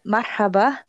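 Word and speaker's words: marhaba (مرحبا): Yeah, so the H kind of sound, it's very, it's coming from like your throat, like the back of it. Yeah, okay. so that's marhaba. And that's like, if you were to spell it in like English, I guess it'd be like M-A-R-H-A-B-A.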